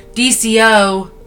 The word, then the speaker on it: DCOM: It is a DOC. Wait, I said that wrong. DCOM.